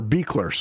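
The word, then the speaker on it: Buechler: So John Carl Beckler, or Beekler, sorry. It's spelled very not how it's pronounced, but it is pronounced Beekler.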